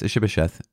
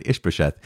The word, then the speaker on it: Ish-bosheth: Well, not only because you can pronounce Ish-bosheth both the way I pronounce it and Ish-bosheth, but because of the fact that here is David who wants to be an honorable man.